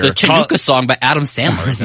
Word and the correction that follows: Hanukkah: I don't know how do you say it? – The Hanukkah song? – Oh, is that what it is? – Oh, yeah.